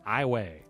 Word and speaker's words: Iowa: For those of a certain generation, Iowa County is pronounced Iway.